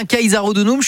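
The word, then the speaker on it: Caesarodunum: Et on dit bien Kaiser Hodonum, je suis rassuré, j'avais un petit doute sur la prononciation, donc c'est parfait. – On a la prononciation officielle.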